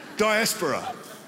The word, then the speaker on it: diaspora: Hellenist Jews who were Greek speaking Jews and Jews who were part of, the, what's that word, dysphora? How do you say that word? Dysphora? There's no college teacher here who can, yeah. That's what I said. Diaspora.